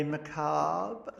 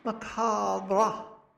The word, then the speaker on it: macabre: But you love the way the Americans say macabre. They say macabre. Macabre. Macabre. I think it's best that we just cut off the R-E and then it becomes, oh dear, macabre? No, let's leave the R there, and it's macabre, here on 94.9 Main FM's Dark Moments Returns.